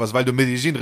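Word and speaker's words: Medellín: waren in und so sprechen die es aus. Die waren in Medellin. Ja, das ist ja auch richtig ausgesprochen auf dem Dialekt.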